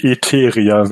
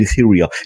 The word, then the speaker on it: Etheria: Aber bei den beiden Planeten würde ich auch die englische Variante tatsächlich nehmen. Eternia und Etheria.